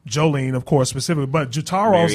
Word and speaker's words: Jotaro: Jotaro. I think that's how you say it. Jotaro. – Yeah. You're right.